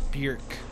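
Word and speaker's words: Björk: Oh, and I learned through her being on the episode, her name is actually pronounced Bjork, like really? – the word jerk, but with the word, letter B in front of it.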